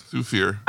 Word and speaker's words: Thufir: Thufir.